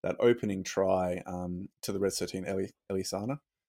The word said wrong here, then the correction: Elisaya: I, have said that wrong. – Elisaya. Sorry, but I don't have it up in front of me anymore. Um, was thank you, Elisaya.